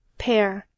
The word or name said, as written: pair